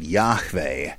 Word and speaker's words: Yahweh: Supposedly, it would be best to pronounce it Yahweh, as I often do.